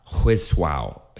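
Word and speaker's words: Huizhou: So the first one's going to be Hallstatt Hisswau. Hisswau. I, I believe I'm pronouncing that's H U I Z H O U if you want to look it up.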